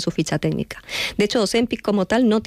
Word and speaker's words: Ozempic: Estos medicamentos como Ozempic, que ahora sí lo digo bien, Ozempic, salieron al mercado para tratar no la obesidad, sino la diabetes.